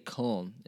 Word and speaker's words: Köln: Yeah, but we would say Köln in German,